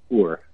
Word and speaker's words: Uhr: The H is silent in Uhr.